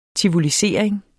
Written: tivolisering

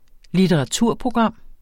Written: litteraturprogram

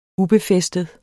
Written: ubefæstet

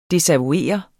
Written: desavouere

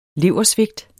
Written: leversvigt

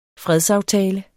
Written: fredsaftale

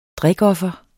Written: drikoffer

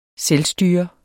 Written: selvstyre

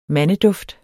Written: mandeduft

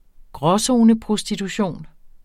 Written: gråzoneprostitution

